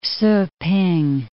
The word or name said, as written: Ssu-p'ing